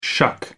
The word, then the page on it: shuck: American English